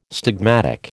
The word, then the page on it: stigmatic: American English